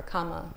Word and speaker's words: kamma: And traditionally, it includes two of the Buddha's really core teachings, the teaching on the Four Noble Truths and the teaching on karma, or kamma, as it's pronounced in the Pali.